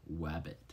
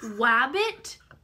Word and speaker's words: wabbit: The word is wabbit. – Wabbit? – Wabbit. And I do not have an Elmer Fudd lisp, and it's not rabbit. – Can you spell it? – W-A-B-B-I-T.